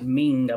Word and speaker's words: Meng: And also, I did watch the Jean Pascal versus Fanlong Meng. I think they were pronouncing it Meng, M-E-N-G. I think they call it Ming.